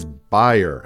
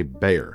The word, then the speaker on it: Bayer: And the next company is Bayer, as David says it, and as I think they say in Germany. Although for the aspirin brand here in the U.S., we say Bayer. So maybe I've been mispronouncing the whole company my entire life.